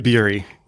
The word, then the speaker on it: Bury: Wyatt Bury.